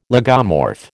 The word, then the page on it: lagomorph: American English